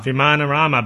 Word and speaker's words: Vimanarama: Another book, I picked up the first two issues of Grant Morrison and Philip Bond's, how would you pronounce that? Vimanarama. – Vimanarama, baby.